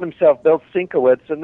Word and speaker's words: Sienkiewicz: And then I just met him at this, you know, this just past MegaCon, and he pronounced it Sienkiewicz.